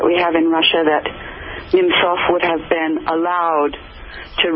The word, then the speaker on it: Nemtsov: First, the death of Boris Nemtsov.